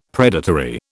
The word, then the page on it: predatory: British English